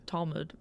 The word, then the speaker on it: Talmud: Talmud.